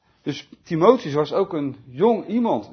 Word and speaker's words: Timotheus: 1 Timotheus 4 vers 12.